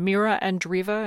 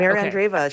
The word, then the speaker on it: Andreeva: And what about, in the just minute we have left, this 16-year-old Russian, Mira Andreeva? Am I saying that right? – Yes, you are. Yeah, Mira Andreeva.